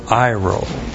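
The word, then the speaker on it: airo: And then the verb iro. A-I-R long O. Iro.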